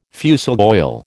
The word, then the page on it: fusel oil: American English